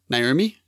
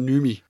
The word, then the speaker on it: Noomi: Naomi? – Yeah. – There's no A. I call it Noomi. – What? No A. What is it? N-O-M-I? – N-O-O-M-I.